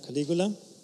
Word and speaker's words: Caligula: Caligula? Yeah, Caligula. So according to the Roman historian Suetonius, there was an astrologer called Thrasyllus, and he had made a prophecy that Caligula had no more chance of becoming emperor than of riding a horse across the Gulf of Baiae.